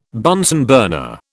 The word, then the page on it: Bunsen burner: British English